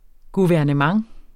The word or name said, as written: guvernement